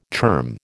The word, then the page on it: term: American English